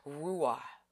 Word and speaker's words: ruach: Now the word for spirit, a good word, if you'll write this down in your journal, is R-U-A-C-H, which sounds like ruah. You know, it sounds like the C is silent.